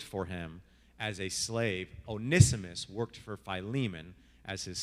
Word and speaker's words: Onesimus: So it's pronounced Onesimus. So Onesimus, this slave, this servant, the entire plot of this is that he robbed Philemon and he ran away to Rome.